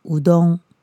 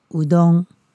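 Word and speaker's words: うどん: 这个在日文的发音呢叫做乌冬，乌冬。那台湾这边呢，应该是取日本的谐音，应该是发音过来，然后嗯加上我们的汉字，把它写成乌龙面。我在街上呢，也有看到有人是写乌冬面，乌冬面其实发音也蛮接近的。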